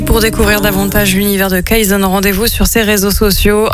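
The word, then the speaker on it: Kayson: Et l'année prochaine, Kayzone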